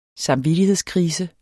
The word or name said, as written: samvittighedskrise